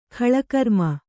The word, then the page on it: ಕಾಲಕರ್ಮ: kannada